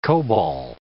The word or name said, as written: COBOL